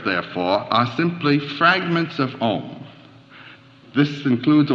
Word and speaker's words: Om: Now in Sanskrit, the syllable Om, the sound O, is analyzed into two sounds, A and U joined, A, U, M. So you'll sometimes see this written O-M and sometimes A-U-M. When written A-U-M, it is called the four-letter sound. I'm going to explain what the four letters are. When you pronounce this syllable, ah starts in the back of the mouth. Oo, the sound fills the mouth cavity, and then m, it closes at the lips.